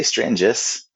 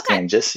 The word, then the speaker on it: Strangis: Pretty close. I say Stranges. – Stranges? Okay. – Stranges, yeah.